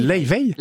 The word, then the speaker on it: Laufey: Nous continuons de philosopher quelques instants avec notre invité et Lofé. – Leivey. – Comment ? – Elle est islandaise, Leivey. – Ça se dit comme ça ? – Ça se dit Leivey. Ça s'écrit L-A-U-F-E-Y, Leivey.